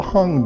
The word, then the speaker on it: Hang: It's called the Hong Drum, and his new CD is The Roswell Incident, Music for Hong Drum, spell that H-A-N-G.